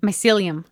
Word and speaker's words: mycelium: I had a question about how do you say the word again? Mycelium? – Mycelium.